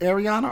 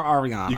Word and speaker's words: Ariana: Is it Ariana or Ariana? – You can pronounce it either way.